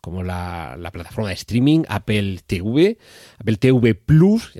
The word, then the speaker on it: Apple TV Plus: ya sé que les gusta que sea TV Plus, pero es que la T y la V en español se pronuncian así, y Plus, o un signo más, es que en español, si nos referimos a la pronunciación del latín, se pronuncia así, Apple TV Plus.